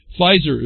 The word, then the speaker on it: Pfizer: Pfizer is a silent P.